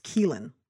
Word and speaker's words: Caoilfhionn: And so the next actor's name, which plays the nurse, is spelled C-A-O-I-L-F-H-I-O-N-N. So I'm assuming this is like Gaelic. Believe it or not, that is pronounced Keelan.